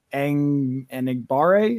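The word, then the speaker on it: Enagbare: Kingsley Enigbare is how it's been pronounced.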